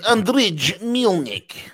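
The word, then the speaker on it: Andriy Melnyk: His name is Andriy Melnik.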